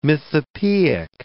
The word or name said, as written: mythopoeic